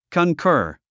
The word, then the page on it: concur: kənkə́ːr; カンカァ